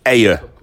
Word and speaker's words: Ayr: Is that how you pronounce Ayr? – A-Y-R. Ayr. – Is that how they pronounce it? In Ayr? Is it Ayr? – Yes. – Ayr. Ayr. – Pronounced it right.